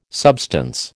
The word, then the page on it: substance: American English